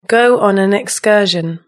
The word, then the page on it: go on an excursion: British English